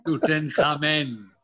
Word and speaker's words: Tutankhamun: And they had this very famous king, King Tutankhamun. What was his, how do you pronounce his name? – Tutankhamun.